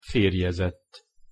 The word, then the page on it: ferjezett: Hungarian